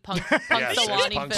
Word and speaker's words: Punxsutawney: How do you say his name? Punxsutawney? – Punxsutawney Phil. Punxsutawney Phil.